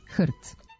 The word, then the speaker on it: HRT: S druge strane, skraćenicu za Hrvatsku televiziju ne izgovaramo slovo po slovo, nego kao jednu reč, HRT. Taj nam je izgovor blizak jer je to homonim za reč kojom se označava pasmina pasa i koja odavno postoji u našem jeziku, ima slogotvorno R, što olakšava izgovor i srodna je sa rečima krt i vrt.